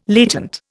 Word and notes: latent: Indian

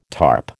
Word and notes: tarp: American English

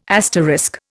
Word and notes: asterisk: Indian

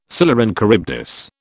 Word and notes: Scylla and Charybdis: British English

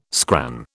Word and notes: scran: British English

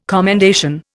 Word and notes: commendation: Indian

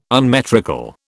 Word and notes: unmetrical: British English

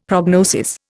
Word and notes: prognosis: Indian

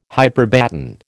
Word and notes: hyperbaton: American English

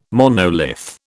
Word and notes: monolith: British English

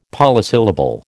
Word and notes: polysyllable: American English

